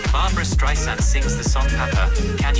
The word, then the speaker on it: Streisand: En van achteren heet ze dus Streisand, niet Streisand. Die uitspraak stoorde haar zo ooit dat ze Tim Cook van Apple belde om te klagen over Siri. – It's dry sand, like sand on the beach.